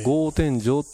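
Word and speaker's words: 格天井: でこれ天井の形が少し変わっていまして、格子の天井木片におのおののおのと書きまして、えー、豪って読むんですけども、えー、豪天井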